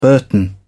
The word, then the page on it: burton: British English